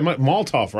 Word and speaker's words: Molotov: Why can't I say that? Molotov, right? – You are saying it right.